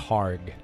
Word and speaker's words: McHargue: Mike McHarg.